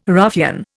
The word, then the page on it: ruffian: Indian